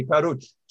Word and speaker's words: Pahud: I want to say it correctly, Pahud.